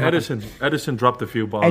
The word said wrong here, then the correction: Ederson: Ederson is how you pronounce it.